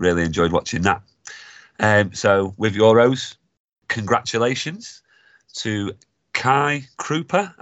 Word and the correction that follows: Kai Krupp: Am I pronouncing that correctly? – Don't ask me. Kai Krupp. – Kai Krupp. Yeah, Krupp.